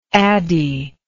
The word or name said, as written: addie